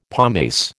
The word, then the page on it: pomace: American English